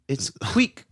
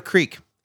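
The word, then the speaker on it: kveik: So it's queek, queek. – What? – It's pronounced queek. – Is that right? – It's the hottest new. – This is my favorite pronunciation episode we've ever done. – Centuries old beer yeast. It's not a creek. It's a queek.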